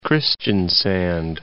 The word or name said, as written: Kristiansand